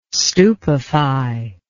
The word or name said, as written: stupefy